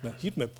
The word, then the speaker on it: Heatmap: Noch ein zweites Beispiel, jetzt kommen wir zu dieser Headmap. Heißt jetzt richtig ausgesprochen, Thomas? Heatmap war schon richtig, war ich eigentlich auch der Meinung.